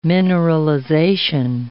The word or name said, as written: mineralization